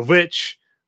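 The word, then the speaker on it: VVitch: And then The Witch, or The Vivitch, because it's spelled with the VV, like old school typesetting.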